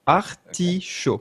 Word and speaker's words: artichaut: Do you know what an artichaut is? – No, I don't even think I can pronounce it. Is it an artichaut? C'est ça? – Perfect. Artichaut. Okay.